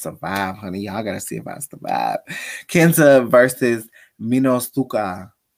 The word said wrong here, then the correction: Minoru Suzuki: Nah, how you say it, girl? – I don't even forgot how to say it. Minoru Suzuki is what his name.